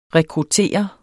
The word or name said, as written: rekruttere